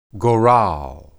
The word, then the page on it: goral: hebrew